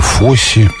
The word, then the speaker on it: Фосс: Боже мой, как хорошо, что наш этот поздний час в эфире так мало слушателей, еще меньше слушателей помнят сегодня гениальный фильм Боба Фосса, точнее, произнося Боба Фосси,